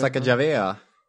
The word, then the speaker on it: Sacagawea: Ja, uttalar Sacagawea.